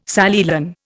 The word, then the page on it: Sally Lunn: Indian